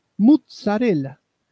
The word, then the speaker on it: mozzarella: Se debe pronunciar de la misma forma que pizza, mozzarella.